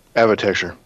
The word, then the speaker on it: Avatecture: Avatecture. – Thank you. I even wrote it phonetically. – Of architecture, but with AV in the front.